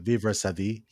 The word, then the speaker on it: Vivre sa vie: Do you think I'm pronouncing that correctly? Vivre sa vie? – Vivre sa vie. Yeah, sure.